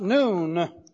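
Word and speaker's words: noon (נון): And it's pronounced noon.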